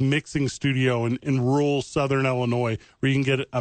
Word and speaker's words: Illinois: The S is silent at the end of Illinois. I don't know why people You just say Illinois. Don't say Illinois.